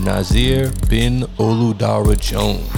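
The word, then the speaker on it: Nasir bin Olu Dara Jones: And today our notable mention is for Mr. Nasir Bin Oludara Jones.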